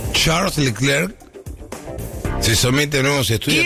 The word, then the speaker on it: Charles Leclerc: Charles Leclerc.